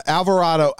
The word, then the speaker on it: Alvarado: Is that how I pronounce it? – Yeah, Alvarado. I would say,